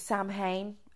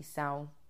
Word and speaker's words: Samhain: Some people think it's Samhain, how it's spelt, but it's actually sound.